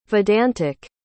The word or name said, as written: vedantic